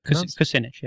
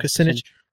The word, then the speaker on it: Kucinich: I think that's how his name pronounced. – Kucinich, yeah. – Kucinich. I'm going to pronounce it Kucinich because that's awesome. – Because it's more accurate, I think.